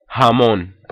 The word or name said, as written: jamon